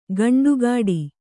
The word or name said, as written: ಖಂಡಗಟ್ಟು